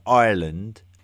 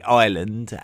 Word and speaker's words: Ireland: There's a sort of a lazy English pronunciation of the country of Ireland, where we tend to call it Ireland, as to rhyme with the very generic term for a landmass in the middle of the sea, an island. So I try and make a conscious effort to say Ireland instead of island.